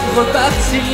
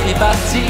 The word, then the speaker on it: repartir: Le E se prononce E. Y'a pas d'accent, regarde. Aïe, repartir. Pas réparti. Non, c'est pas réparti.